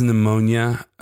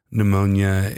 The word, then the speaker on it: pneumonia: I feel like there's some joke to be made that pneumonia is the pterodactyl of diseases, but it's not really that funny. And I don't know if it's technically a disease. They just both have a silent P up front.